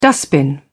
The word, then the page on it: dustbin: British English